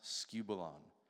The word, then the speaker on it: skubalon: The Greek word that he used there is pronounced skubalon,